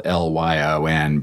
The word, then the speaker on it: Lyon: As early as September, Winthrop dispatched the most competent captain of the fleet, William Pierce of the Lion, spelled L-Y-O-N, but pronounced like the fearsome cats, to Bristol to fetch new supplies and more settlers.